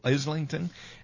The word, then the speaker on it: Islington: But "We Don't Need No Education" was sung by students from a school in Islington, England. Did I pronounce that correctly? – I think so. – Islington.